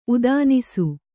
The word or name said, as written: ಉದಾನಿಸು